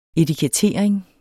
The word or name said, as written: etikettering